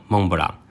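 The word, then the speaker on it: Mont Blanc: 发扬光大的，就是一家位在自由之丘的杨氏甜点店，店名就叫做蒙布朗。蒙布朗最早的起源是在法国的萨瓦省和意大利的皮埃蒙特。这两个地方刚好被阿尔卑斯山脉的最高峰、标高四千八百一十公尺高的白朗峰所区隔。白朗峰的法文就念作蒙布朗，意思就是白色的山峰，因为山顶上永远积着一层厚厚的雪而得名。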